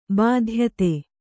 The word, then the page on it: ಬಾಧ್ಯತೆ: bādhyate